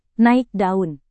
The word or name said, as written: naik daun